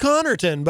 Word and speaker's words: Connarton: It's pronounced Connarton, but it has been pronounced many different ways over the years. One of them, Hammer from Rage Rock Radio, used to pronounce it in a way that I can't even say on FM radio. But you're close. You're close.